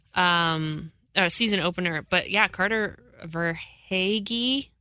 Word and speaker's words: Verhaeghe: And we apologize if we're butchering it, but we searched the social media webs, interwebs, for how to pronounce Carter Vaghi. Did I do it right? – No, you didn't. – Verhaeghe. Carter Verhaeghe. We'll